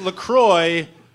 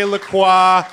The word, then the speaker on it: LaCroix: It is LaCroix rhymes with enjoy. Simple as that. It's over. There's no more debate about it. If you ever hear somebody say LaCroix, you can tell them they're being a snob and that they're wrong.